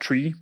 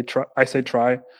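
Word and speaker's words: trie: And it's basically, if you're familiar with the term a prefix try, it's very confusingly named in English because it's T-R-I-E, and some people pronounce it tree, but it's not a tree as in T-R-E-E. So I say try,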